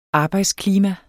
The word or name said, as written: arbejdsklima